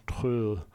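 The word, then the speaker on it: Troell: Ein Freund von mir, gut, würde sagen ein Bekannter, nicht ein echter Freund, ich kannte ihn nicht sehr wenig, aber heißt Stephen Trell, deutsch ausgesprochen Tröll, O-E-L-L.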